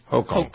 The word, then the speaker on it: Hochul: Is that how you pronounce his name? – Hochul. – Yep. There's a hoax. Hochul.